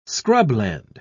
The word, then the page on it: scrubland: British English